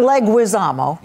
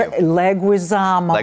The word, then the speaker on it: Leguizamo: And then it is John Leguizamo. – Yes, thank you. – Leguizamo.